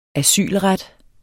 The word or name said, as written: asylret